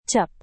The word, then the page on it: cup: Indonesian